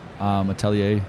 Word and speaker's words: atelier: Atelier actually has a silent R. So it's A-T-E-L-I-E-R.